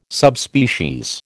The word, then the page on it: Subspecies: American English